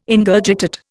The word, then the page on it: Ingurgitate: Indian